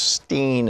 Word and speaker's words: Rosenstein: Rapidly changing events in the Rod Rosenstein world, or Stein world, excuse me. I heard over the weekend that he says it's fine to pronounce it either way. I had heard some weeks ago, maybe months ago, actually, that, you know, in the right-wing world it was Steen, and in the real world it was Stein, and who knows, I don't know.